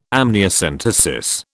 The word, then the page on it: amniocentesis: British English